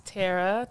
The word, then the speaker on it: Terra: Hi guys, my name is Terra.